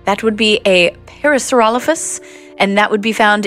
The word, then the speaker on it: Parasaurolophus: Parasaurolophus.